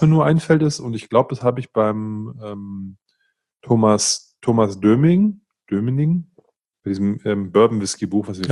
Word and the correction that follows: Dominik: Thomas Dominik. – Haben. Thomas Dominik, Entschuldigung, lieber Thomas, dass ich deinen Namen falsch ausgesprochen habe.